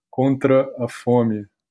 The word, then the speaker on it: contra a fome: Maybe with my Carioca accent from Brazil, my Brazilian Portuguese is RID yoga contra a fome.